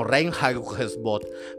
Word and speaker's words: Reinheitsgebot: Es que ya los alemanes traían algo desde 1500 con lo de la pureza y la ley conocida como Reinheitsgebot marcó una historia, oye lo pronuncié bien, antes y después de la cerveza.